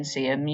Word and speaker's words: Seaham: And you live in, is it Seaham? Am I pronouncing that right? – Yes. – Seaham.